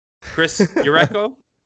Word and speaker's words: Jericho: they pronounced Chris Jericho's name as if he was Jonas Jerebko's brother. Chris Jericho.